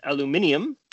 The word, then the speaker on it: aluminium: And then aluminum, though, of course, since this paper is out of Europe, they pronounce it aluminium.